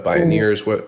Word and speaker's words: Bioneers: And that sort of rhymes with pioneers, right? – Like right, but it's B like biology, B-I-O-N-E-E-R-S.